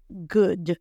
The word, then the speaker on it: good: Ocurre algo muy típico, que es la combinación de las vocales o o. La mayoría de las veces, no todas, porque siempre hay excepciones, la mayoría de las veces la combinación o o en inglés equivale a un sonido de u. Uh, por eso bueno se dice good o la palabra comida, ¿cómo se dice?